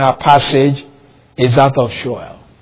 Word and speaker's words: Sheol: See, that phrase, the land of gloom and deep shadow, is a description of Sheol, the place of the dead. S-H-E-O-L, so just in case you don't know what I'm pronouncing, Sheol.